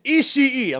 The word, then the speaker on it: Ishii: Moxley versus Ishii, excuse my American. – Filthy that Gaijin. – I can't help it. I'm sorry. – You say it right. Ishii.